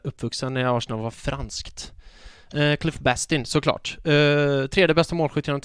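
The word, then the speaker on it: Cliff Bastin: Hur uttalar man hans namn? Jag säger jämt Bastien med fransk accent, men han är ju inte fransman. – Cliff Bastien. – Cliff Bastien, såklart, ja.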